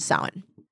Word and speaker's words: Samhain: Yeah, it's pronounced Samhain.